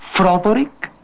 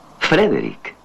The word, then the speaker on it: Frederick: ¿Dice usted también Froderick? – No, Frederick. – ¿Y por qué no es Froderick Frankenstein? – Porque no, es Frederick Frankenstein.